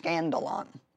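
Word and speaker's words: skandalon (σκάνδαλον): So, offense, if you study the word in the Greek, original Greek, it comes from a Greek word pronounced skandalon. And this is actually what it says the definition is.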